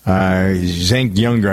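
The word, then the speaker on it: Cenk Uygur: Zenk Younger, I never can pronounce his name. Can you do it better than I do? – No, that's about right.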